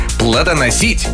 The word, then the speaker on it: плодоносить: Как нужно произносить? Кухонный, плодоносить, таинство, жерло. – Да-да-да. Внезапно. – Жерло и лассо.